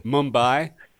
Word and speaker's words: Mumbai: Mumbai.